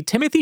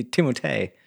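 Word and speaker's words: Timothée: I'm your host, and tonight, playing the part of Caleb Coy will be Timothy Chalamet. – I'm told it's actually Timotei. – Oh, is that how it's pronounced? – And apparently just gave up correcting people.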